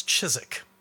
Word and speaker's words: Chiswick: So, for instance, it's not Chiswick bitter, it's Chiswick.